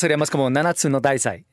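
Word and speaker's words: Nanatsu no Taizai: de Nanatsu no Taisai,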